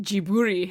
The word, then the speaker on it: Ghibli: I was just thinking, I was like, did you just like Western Asianify the No, so apparently he pronounces it Jiburi himself, because they don't have L's.